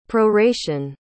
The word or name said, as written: proration